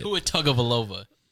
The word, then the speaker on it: Tua Tagovailoa: Tua Tagovailoa? – No. – Actually, that's not his last name. I don't know how to say it. – Tua Tagovailoa. – Tua Tagovailoa.